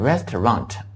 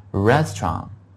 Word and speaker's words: restaurant: 还有就是餐馆这个词啊，餐馆这个词我们可以读得轻巧一点，就是把最后那个 T 呢读得轻一点啊，不要读成 restaurant, 那就很难听的，就是 restaurant OK。